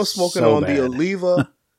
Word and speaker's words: Oliva: Is it Aliva? – Oliva. Like Olivia. – Oh, okay. – The I after the, before the A. So it's Oliva.